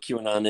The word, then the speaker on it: QAnon: I don't even know what QAnon is. – You, you just pronounced it right. I repeated what you said. I pronounced it wrong. You pronounced it properly.